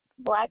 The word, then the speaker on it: Black: K-E-L-A-H. – Okay, thank you. And can you give me your last name, please? – Black, like the color. – Okay, I'm going to repeat that back to you. That's Keela Black, K-E-L-A-H, last name Black, B-L-A-C-K.